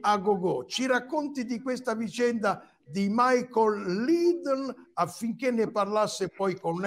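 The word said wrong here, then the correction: Michael Ledeen: Michael, sì, la pronuncia è detta Michael Ledin.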